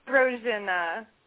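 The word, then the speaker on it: Brozyna: It's Brosna.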